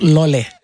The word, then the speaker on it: Lole: Le titre c'est Loli. – Comment tu dis? How do you pronounce it? – Lole. – Lole. – Ok, Lole. Ouais.